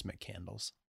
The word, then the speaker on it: McCandless: I pronounced it wrong. It's Mick Candles, which is still pretty cool.